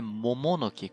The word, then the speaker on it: Momonoki: É Mononoki, a pronúncia certa? A pronúncia é Momonoki. – Com Momonoke. Dois M, M, ah, é, com né? M, M, isso.